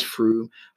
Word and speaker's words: Frome: Froome, that's spelled F-R-O-M-E. So occasionally people from outside the area tend to mispronounce it as Frome, but it is Froome, and you'll find it in the southeast part of Somerset, not actually very far away from the county boundary with Wiltshire.